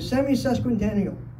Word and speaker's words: semi-sesquicentennial: Next year is the semi-sesquicentennial.